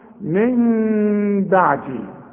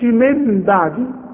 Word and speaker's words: من بعدي: يبقى هنا في ثلاث احكام العلماء بيقولوا: اقلاب هنقلب النون الى ميم، ونخفيها بالشفايف اخفاء مع الغنة، واسمع نطقها من بعدي، ما اقولش من بعدي واقولها نون، ولا اقولها ميم خالصة واقول من بعدي، يحصل انفراد في الشفايف.